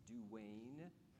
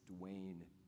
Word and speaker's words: Duane: My name is Duane. Sometimes I overemphasize my name. It's like, is that really how you say your name? It's Duane. Is the other Duane here, Duane Sharp? My other Duane. There's two Duanes. D-U-A-N-E.